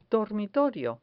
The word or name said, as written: dormitorio